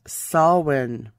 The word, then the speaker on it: Samhain: Now, it looks like it would be spelt Samhain, S A M H A I N. It's an Irish pronunciation pronounced Salwyn.